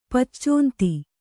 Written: ಪಚ್ಚೋಂತಿ

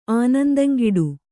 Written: ಆನಂದಂಗಿಡು

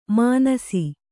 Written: ಮಾನಿಸ

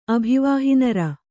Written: ಅಭಿವಾಹಿನರ

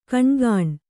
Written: ಘಿಣಿಘಿಣಿ

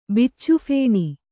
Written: ಬಿಚ್ಚುಫೇಣಿ

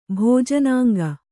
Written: ಭೋಜನಾಂಗ

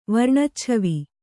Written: ವರ್ಣಚ್ಛವಿ

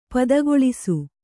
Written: ಪದಗೊಳಿಸು

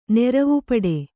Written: ನೆರವುಪಡೆ

